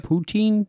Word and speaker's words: poutine: So where's the poutine? You got to point that out. Is that how you say it? Poutine? – Poutine.